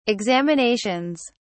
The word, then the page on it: examinations: British English